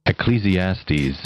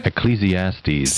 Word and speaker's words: Ecclesiastes: Ecclesiastes. – Got it. Say it again. – Ecclesiastes. – Thank you. One more time. – Ecclesiastes. – How can we? Ecclesiastes.